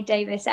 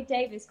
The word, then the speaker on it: Davies: Well, it's spelt Davies, but it's I say Davies. I literally have this conversation with so many people. But yeah, I say Davies because that's what I've said my whole life, but it is spelt Davies, yeah.